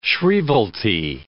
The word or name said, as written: shrievalty